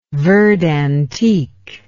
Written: verd antique

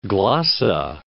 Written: Cape Glossa

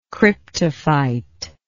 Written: cryptophyte

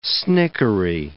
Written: snickery

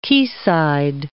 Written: quayside

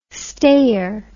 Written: stayer

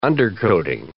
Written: undercoating

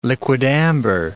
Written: liquidambar

